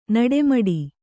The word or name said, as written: ನಡೆಮುಡಿ